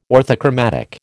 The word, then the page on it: orthochromatic: American English